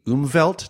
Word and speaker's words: Umwelt: If time itself isn't necessarily a sense, there is this vast sensory realm that you and others refer to as the Umwelt.